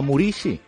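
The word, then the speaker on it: Muriqi: Parlant del Mallorca, Vedat Muriqui, o Murici, com eh, diuen que s'hauria de pronunciar,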